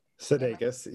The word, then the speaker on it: Sudeikis: And it, it provided this type of hope that I feel like we all need right now because um, Jason Sudeikis, I'm not saying his name right. – Sudeikis, yep.